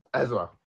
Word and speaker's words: Ezra: Urza. – Ezra. You can't even say his name right. – Urza. It's an E. It should be Urza.